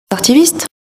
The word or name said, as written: artiviste